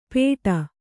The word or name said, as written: ಪೇಟ